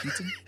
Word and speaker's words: Keaton: Oh, am I saying his name wrong? Michael Keaton? – Keaton.